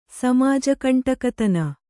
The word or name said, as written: ಸಮಾಜಕಂಟಕತನ